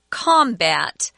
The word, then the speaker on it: combat: And this word combat is pronounced differently based on whether it's a noun or a verb. If it's a noun, like it is here, we say combat.